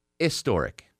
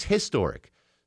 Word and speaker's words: historic: Apparently, the old pronunciation of historic up until the 19th century was historic. The H was silent.